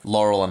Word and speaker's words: Laurel: Like Lyle and Hardy. – Mm. – Is that how you say it, Lyle? – Laurel and Hardy.